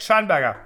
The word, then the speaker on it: Scheinberger: Yeah, that's super perfect. Scheinberger. Yep.